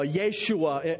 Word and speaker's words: Yeshua: That's actually the name in The original Hebrew, his name is Joshua, Yeshua. Anybody ever heard their Hebrew roots friends talk about Yeshua? It's just that's the way that Joshua would have been pronounced.